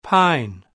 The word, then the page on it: pine: British English